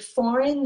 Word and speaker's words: foreign: So in the word foreign, the G is silent. We do not pronounce the G.